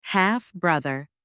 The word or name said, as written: half-brother